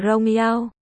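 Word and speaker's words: rong yao (荣耀): Hai, vinh diệu đọc là rong yao, thuốc trừ sâu đọc là nông yao.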